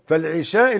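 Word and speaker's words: العِشاء: فالعشاء اللي هي الصلاة بكسر العين، والعشاء اللي هو الأكل بفتح العين.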